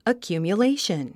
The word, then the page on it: accumulation: əkjùːmjuléiʃən; アキュミュレイション